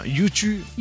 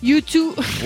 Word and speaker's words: U2: U2. – U2.